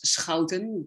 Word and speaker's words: Schouten: Well, Barbara, my last name is Schouten, but don't worry if you cannot pronounce it, because nobody outside of the Netherlands can pronounce it.